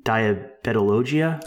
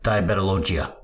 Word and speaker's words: Diabetologia: As I was looking for some background to look up the mechanism of action, I pulled up this interesting paper from, I never can say this journal name, Diabetologia. Is that how you say it? – I don't know. – That's exactly how you say it. In fact, the editor always calls it Diabetologia.